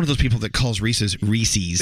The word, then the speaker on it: Reese's: It doesn't rhyme with feces. – It's Reese's pieces.